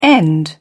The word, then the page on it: end up: British English